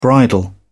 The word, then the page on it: bridal: British English